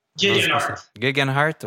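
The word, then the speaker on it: Geoghegan Hart: que dont je peine à prononcer. Guggenhardt. – Gegenhardt. – ok, voilà. À gang, on va finir par le prononcer comme du monde.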